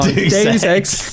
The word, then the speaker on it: Deus Ex: I always say it wrong. Deus Ex. Because it's like Deus.